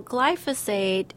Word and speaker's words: glyphosate: So glyphosate is the... – There it is, glyphosate. I'm sorry I butchered it.